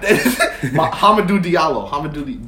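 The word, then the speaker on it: Hamidou Diallo: Mamadou Diablo. – But Hamadou Diallo. Get the name right.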